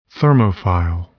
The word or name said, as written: thermophile